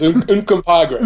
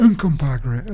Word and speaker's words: Uncompahgre: Yes, Uncompahgre. – Uncompahgre.